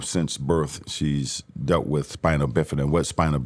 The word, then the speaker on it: Spina Bifida: The Spina Bona? – Spina Bifida. My youngest daughter. Yeah, it's a tongue tie. I mean, Spina Bifida.